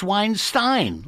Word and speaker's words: Weinstein: But it's Weinstein, like Einstein.